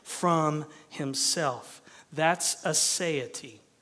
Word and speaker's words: aseity: And the word is this: aseity. A-S-E-I-T-Y, if you're wondering how to spell it.